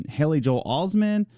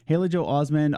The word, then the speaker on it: Haley Joel Osment: It has Haley Joel Osment. I said that name wrong. Haley Joel Osment.